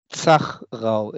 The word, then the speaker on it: Zachrau: So, Thomas, tell us how to pronounce your last name. – Zachrau. It's Z-A-C-H-R-A-U.